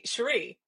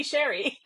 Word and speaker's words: Cherie: No, it's a funny story because my dad named me Cherie. He uh-huh. loves that name. My mom's Korean, and so she could never pronounce Cherie. – Yes. – she calls me Sherry.